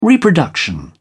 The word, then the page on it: reproduction: British English